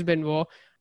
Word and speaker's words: Benoit: His name was Chris Benoit, or as people in India used to call him, Chris Benoit. The pronunciation is Chris Benoit.